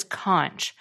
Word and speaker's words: conch: It's spelled C-O-N-C-H, which I pronounced as conch, but it's actually pronounced conch.